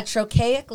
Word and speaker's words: trochaic: It is simply that a trochaic line is pronounced dada, as in highway. The first syllable is stressed and the second syllable is unstressed.